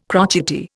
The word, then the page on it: crotchety: Indian